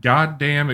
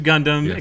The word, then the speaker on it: Gundam: Mobile Suit Goddamn Extreme versus Maxi Boost On. – Yeah, you did well. I mean, you purposely mispronounced Gundam, but I'll accept it.